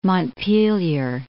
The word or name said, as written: Montpelier